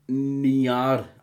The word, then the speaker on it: Niar: Mein Insta-Name wird Niar ausgesprochen. Rollendes R hinten dran.